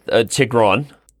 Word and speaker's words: Tigran: Tigran.